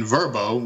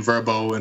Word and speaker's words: Vrbo: In addition to direct bookings on our website, we do list our homes on popular platforms like Airbnb, HomeAway, and Vrbo, which is new pronunciation, Vrbo instead of VRBO, which it was formerly known as.